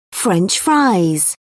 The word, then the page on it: french-fry: British English